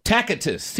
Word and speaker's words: Tacitus: I think it's Tastic. – What number? – I don't even know his number. – It's number 8. It's the horse's name. Tacitus. Tacitus, yeah. – T-A-C-I-T-U-S. Tacitus.